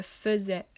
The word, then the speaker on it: faisais: Je vous rends juste attentif au fait que pour le verbe faire, le début euh, s'écrit F-A-I-S, donc le A-I normalement, Souvent le son fait et, mais ici quand on le lit, on dit je faisais. On dit e, mais ça s'écrit ai. Donc je vous rends juste attentif à ce, cette petite particularité. Hein. on dit bien e, mais on écrit ai.